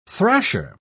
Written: thrasher

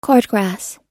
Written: cordgrass

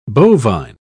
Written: bovine

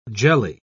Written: jelly

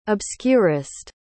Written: obscurest

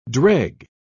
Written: dreg

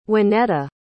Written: wanetta

